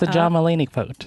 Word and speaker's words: Mulaney: That's how you say his name. – Why did you think you said it? – Mulaney. – Have you never heard anyone say it out loud? – I wasn't listening.